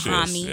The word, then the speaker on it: Dahomey: Am I saying that right? – No, break it down. – Dahomey.